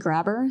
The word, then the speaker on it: Grabr: It's called Grabber. So if you travel to Latin America a lot, it's spelled G-R-A-B-R.